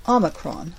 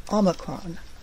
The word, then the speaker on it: Omicron: Omicron. Omicron.